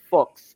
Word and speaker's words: Fuchs: It's spelled F-U-C-H-S. I read somewhere, and I guess his father was an NFL player and is now coaching. And somewhere someone looked it up, his father, the pronunciation, and they said it rhymes with books. So it's Fuchs.